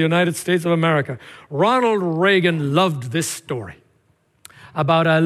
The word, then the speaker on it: Reagan: Just President Ronald Reagan, actually an Irish name and pronounced back there, Regan. But you know it as Reagan.